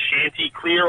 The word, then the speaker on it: Chanticleer: I planted 3 pear trees about 3 months ago or whatever, and 2 of them were, and the other one, I think it's pronounced Chanticleer or something. – That's right. Yep.